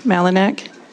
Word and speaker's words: Malinak: Yes, Amber, A-M-B-E-R, Malinak, M-A-L-I-N-A-K.